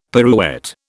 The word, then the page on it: pirouette: British English